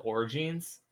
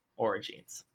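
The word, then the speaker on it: orogenes: How do you say it? – I would go with Origines.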